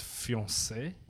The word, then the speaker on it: fiancé: His fiance wrote a children's book about him. – It's actually pronounced fiancé. – Sorry.